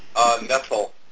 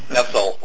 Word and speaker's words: NETL: And so how do you pronounce the acronym? – NETL. – NETL, okay. – NETL, like nestling in, NETL.